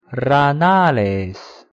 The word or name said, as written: ranales